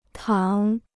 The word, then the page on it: 躺: tǎng